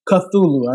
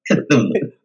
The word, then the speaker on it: Cthulhu: Cthulhu, I know. – Cthulhu.